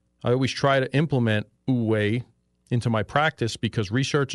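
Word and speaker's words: wu wei: and it's about this 2,000-year-old Chinese concept called Uwei. Now, it's spelled W-U space W-E-I, but it's pronounced Uwei, which translates to non-action or effortless action.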